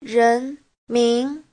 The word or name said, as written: ren2min2